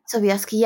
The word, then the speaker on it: Sobieski: And also, I'm not sure whether I'm pronouncing her name right, but is it called Lily Sobieski? – Oh, Lily Sobieski. – Sobieski, yeah.